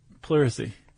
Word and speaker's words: pleurisy: Pleurisy.